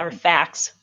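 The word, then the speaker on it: FACS: So you would say that doing the FAC training, Ekman's FAC training, if I'm saying it right. – Or facts. – Facts, yep. Yeah, with the S on that, right?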